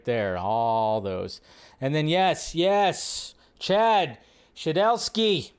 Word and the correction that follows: Stahelski: Stahelski, I never say his name right, says they've had talks about adding best stunts category to the Oscars.